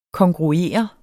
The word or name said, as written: KONGRUERE